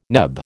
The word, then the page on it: NEB: American English